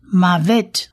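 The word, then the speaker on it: Mavet (מוות): Tav 呢，在发音上面我们念 T 的音，所以这个字整体发起来就是 Mavet， Mavet。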